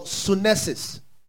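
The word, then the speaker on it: sunesis (σύνεσις): Now, the third kind of understanding is what in the Greek we call sunesis. Sunesis. Spell it the same way I pronounced it. Sunesis. It is, you see, it's also made up of two words. Soon. Not S-O-O-N-O.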